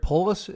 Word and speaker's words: Polis: In your piece on the Federalist, you talk about how Governor Jared Polis, is it pronounced? – Correct.